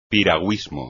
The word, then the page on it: piragüismo: espagnol